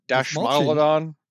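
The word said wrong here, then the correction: Dastmalchian: Dismalchin. Dismalchin, that's how you pronounce it.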